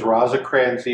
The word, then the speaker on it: Rosecrans: Does Rosecrans even...